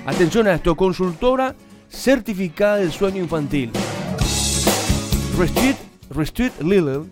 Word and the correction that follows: Rested Little: ¿Está bien pronunciado? – Rested Little. – Ahí está. ¿Cómo, Facundo? Perdón, ¿cómo, Luisfer? – Rested Little.